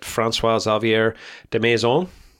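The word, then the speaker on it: François-Xavier Demaison: Williams F1 have announced that François Xavier de Maison, that was good pronunciation.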